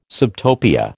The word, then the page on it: subtopia: American English